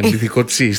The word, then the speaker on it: Μπιθικότσι: Αλλά επειδή δεν θέλανε να το λένε Μπιθικότσι, θέλανε να το λέγανε στα γαλλικά, τονίζονταν όλα στη, ναι, Μπιθικότσι, στη λίγουσα. Έτσι, Μπιθικότσι ακούγανε. Λοιπόν, εντάξει, ωραία.